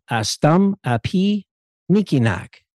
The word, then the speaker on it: Astum Api Niikinaak: And you are also the coordinator of Astum Api Nikinak. – That's right.